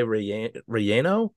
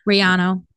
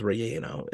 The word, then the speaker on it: relleno: But I will say, Elijah swears by the Chile relleno. – Riano. – Riano. Yano. Don't listen, don't listen, to him. It's relleno. It's spelled relleno. – R e l l e n o. Relleno.